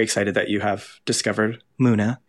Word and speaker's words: MUNA: And also, I don't know how to pronounce this name, but it's either Muna. – Yep, that's it.